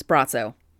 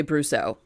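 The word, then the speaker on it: Brosseau: How do you say his name? Brosso? It's Brosso. I always say Brusso, but it's Brosso.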